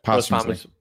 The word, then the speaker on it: posthumously: Um put out three uh two albums, um, and the third post hum- humiously. How do you say that? – Posthumously. Posthumously posthumous yeah posthumously